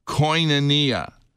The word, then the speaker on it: koinonia: The Greek word that is often translated as communion is pronounced koinonia. It's kind of, it begins with a K-O-I-N and then O-N-I-A.